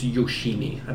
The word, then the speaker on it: Yoshimi: Yoshima battles the pink robots, part one. Or I think I'm saying that right. It's Yoshimi.